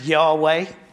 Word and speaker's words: Yahweh: So in the Old Testament, that's just four letters, which you can pronounce Yahweh, or sometimes another way.